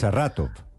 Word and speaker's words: Saratov: Sí, con R más pronunciadas, que yo no sé pronunciar la R. Bien. Saratov.